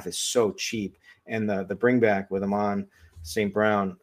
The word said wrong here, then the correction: Amon-Ra St. Brown: I think you might've forgotten a syllable in there somewhere. – Yeah, there. That's what I think. Amon-Ra St. Brown.